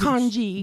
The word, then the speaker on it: kanji: Kanji, or is it kangi? – Huh? Is kanji.